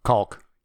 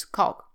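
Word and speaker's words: caulk: That's how it's pronounced. It's pronounced different in England, but the American version is Calk.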